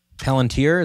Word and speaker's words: Palantir: Palantir, is that how you say it? – Yeah.